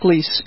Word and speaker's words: Gleis: And Robert, just for clarification, my name is spelled Gleis, but it's pronounced Gleis.